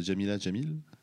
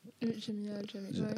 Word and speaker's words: Jamila Jamil: Moi j'aime beaucoup le, Jamila Jamil. – Jamila Jamil.